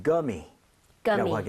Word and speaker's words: gummy: 그런 것을 이렇게 표기하는데 대신에 우리는 구미라고 하는데 영어에서는 gummy라고 하게 Gummy. – 됩니다. gummy, right? So 구가 아니라 g에 가깝게 발음을 하셔야 됩니다.